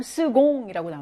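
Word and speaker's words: second: 그다음 두 번째도 두지엠무랑 스공이라고 나오죠. 이걸 스공으로 발음한다 그랬어요.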